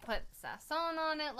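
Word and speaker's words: sazón: I bought this seasoning, and there's this seasoning called sazon.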